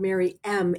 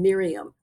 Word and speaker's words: Mary M: We should make sure to say her name is Mary Margaret, and she's called Mary M as in the initial M. – Yes. – Because it sounded like we're saying Miriam, but it's Mary M.